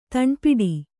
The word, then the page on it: ದಾನಪಟ್ಟೆ: dāna paṭṭe